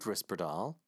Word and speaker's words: risperidone: The FDA approved risperidone, which is how you pronounce it, in late 1993.